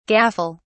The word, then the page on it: gaffle: British English